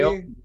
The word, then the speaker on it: Aoki: Aoki, maybe? Aoki came in. Oh yeah, Richard, yeah. That's how you say it.